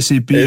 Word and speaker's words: SAP: Comment il prononce SAP ou SAP? – SAP, ouais, c'est SAP. SAP. Effectivement.